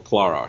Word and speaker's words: Clara: Or Clara, I should say, in the proper British pronunciation.